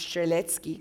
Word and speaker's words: Strzelecki: Surname Strzelecki, which of course should be pronounced Strzelecki because he was a Polish explorer.